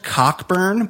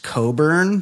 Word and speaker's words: Cockburn: Democratic nominee Leslie, it's spelled Cockburn, but I've heard that name pronounced Coburn before.